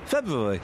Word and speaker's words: February: Can you say February for me? – February. – Exactly.